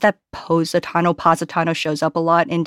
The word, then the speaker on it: Positano: I hadn't realized I was pronouncing Positano incorrectly until the director corrected me that it was Positano and not Positano.